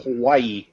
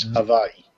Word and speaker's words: Hawaii: People mispronounce it Hawaii, and it's Hawaii.